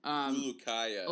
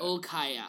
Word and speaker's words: Ulkaya: It's like Hamdi, like, Ulkaya? Yulkaya? He's Turkish. – Ulkaya. – Ulkaya. Hamdi, like, Hamdi Ulkaya.